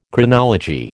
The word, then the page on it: Chronology: American English